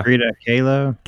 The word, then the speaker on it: Kahlo: Okay, so we have Sammy Rodriguez that says, "Frida, how do you say that? Frida Okoa? – Frida Kahlo. – Kahlo.